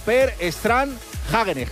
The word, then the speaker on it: Per Strand Hagenes: La ha ganado un noruego, Per Strand Hagenes, que esto se pronuncia así literal, ¿sí? Porque he llamado... – Como los helados, ¿no? – He llamado a Noruega y se me ha puesto un salmón. Me ha dicho que era así.